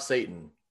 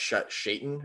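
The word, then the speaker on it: Šatan: However, it is important for you to know that of the four names that I listed, only Miroslav Satan, which I think it's actually pronounced Shaitan or Shaitan, but only Shaitan.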